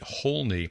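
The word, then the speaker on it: Houlne: and his last name is spelled H-O-U-L-N-E, H-O-U-L-N-E, Holney.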